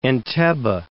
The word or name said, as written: Entebbe